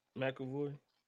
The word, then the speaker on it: McAvoy: McAvoy? – McAvoy.